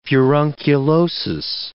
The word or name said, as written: furunculosis